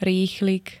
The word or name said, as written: rýchlik